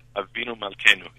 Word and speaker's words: Avinu Malkeinu: Am I pronouncing that correctly? – Avinu Malkeinu, yeah, more or less.